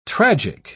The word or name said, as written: tragic